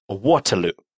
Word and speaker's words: Waterloo: Название произносится на английский манер. Waterloo.